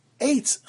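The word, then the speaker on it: Eitz (עץ): In one pasuk it tells us, Ksiv chetz chaniso, that's the way it's spelled, but the karinon, but we read it, we pronounce it Eitz chaniso.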